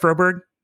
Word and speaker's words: Froberg: Did I pronounce it right, Froberg? – Froberg.